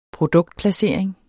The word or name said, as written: produktplacering